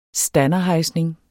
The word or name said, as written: standerhejsning